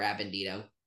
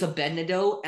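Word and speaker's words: Abednedo: And then like a bunch of us got into a huge argument about whether it was Abednado or Abendido. And I'm like, it's Abednado, and I swear if Pablo Hidalgo himself says the canon pronunciation is Abednado, is Abednido, I will still go, yeah, so Abednado.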